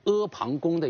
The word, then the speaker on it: 阿房宫: 这是我对阿房宫的一个解释，不仅是它的词义啊，而且包括它的读音啊，这是需要大家要了解的。你最好不要读成阿房宫。